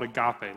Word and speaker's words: agape: Now, the fourth type of love, and this is easily the highest and the most important, is called agape love. And this is agape. Agape! That's the Greek word for it. How do you spell it? A-G-A-P-E. A-P-E.